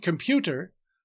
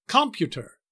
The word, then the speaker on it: computer: In the word computer, the heavier stress is on the middle syllable. We don't say computer or computer.